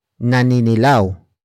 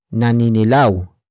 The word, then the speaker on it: naninilaw: Another example, naninilaw ang medyas niya. Naninilaw ang medyas niya. Naninilaw ang medyas niya. It means that person's socks are getting yellowish. Word for word it's naninilaw ang medyas niya. Naninilaw ang medyas niya.